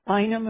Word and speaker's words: Bynum: Democrat Jeanette Bynum, or how do you pronounce that? – Thank you. Bynum, mm-hmm. Correct.